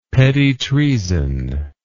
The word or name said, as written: petit treason